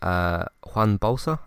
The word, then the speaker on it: Juan Bolsa: Gus plays it cool when he hears from Juan Bolsa, I think is how you say that name. – Yes. – There's a Man United player who's called Juan Mata, and his first name is spelt like that, so that's why I said it that way.